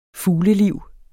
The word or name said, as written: fugleliv